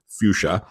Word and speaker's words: fuchsia: I always remember that by just saying fuchsia because it was named after some guy named Fuchs, so that makes it easier to remember. But we all pronounce it fuchsia most people misspell it.